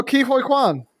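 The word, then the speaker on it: Ke Huy Quan: Is it Ki Hui Kwan? – Oh, Ki Hui Kwan.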